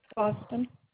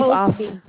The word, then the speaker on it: Austin: Austin. – You said Patricia Austin? – Correct. A-U-S-T-I-N. – It's spelled P-A-T-R-I-C-I-A, right? – Right.